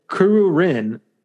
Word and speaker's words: Kururin: Kuru Kuru Kururin, I think that's how you pronounce it. Mario Kart Super Circuit. No, dude, it's Kuru Kuru. Okay, I can get that, but then it's Kururin. K-U-R-U-R-I-N.